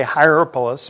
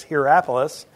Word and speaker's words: Hierapolis: I always want to say Hierapolis, but it's Hierapolis, and they wouldn't even pronounce the H, but it's English, so we will say it that way.